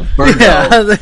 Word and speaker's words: Birdo: From, why'd you say it like she was French? – No, Birdo. – Yeah. – How are you supposed to say Birdo? – Birdo. – Birdo. – You said Bordeaux at first. – Yeah, I didn't say Bordeaux.